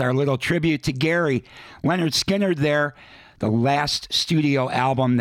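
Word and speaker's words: Lynyrd Skynyrd: That goes back to 1980, in an album called Anytime, Anyplace, Anywhere, and where it all started, the very first Leonard Skinner album, pronounced Leonard Skinner, we heard I Ain't the One.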